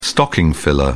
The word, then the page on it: stocking filler: British English